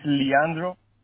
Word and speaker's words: Leandro: Is it Leandro? – No. It's Leandro.